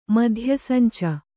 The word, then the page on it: ಮಧ್ಯಸಂಚು: madhya sancu